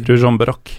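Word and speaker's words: Ružomberok: Han etterforskes jo nå, mens vi sitter her, for en eh, eller etterforskningen kanskje over, eh, en nazihilsen han gjorde etter cupfinalen mot Rusum eh, Ruzjomberok. – Eh, ja, Ruzjomberok, eh, som det selvfølgelig uttales i fjor.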